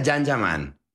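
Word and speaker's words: Can Yaman: Se escribe Jan Yaman, se pronuncia Jan Yaman.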